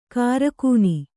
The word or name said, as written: ಕಾರಕೂನ